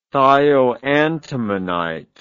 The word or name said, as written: thioantimonite